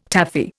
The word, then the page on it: Taffy: Indian